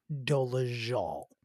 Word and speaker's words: Dolezal: We are speaking, of course, of Rachel Ann Dolezal, because that is how it's pronounced. It is Dolezal.